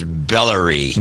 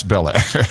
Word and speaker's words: Bel Air: Could be pronounced Bellary. – No, it's Bel Air.